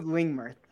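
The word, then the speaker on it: Lingmerth: And then my second pick, I don't even know if I can pronounce this guy's name, but it's Lingmurth. Is that, did I say that correctly? – Yeah, David, yep. Lingmurth.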